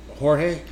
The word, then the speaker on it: Jorge: I, dude, he like George Masvidal. So people ask me. Did you say his name right? Jorge.